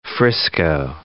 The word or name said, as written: Frisco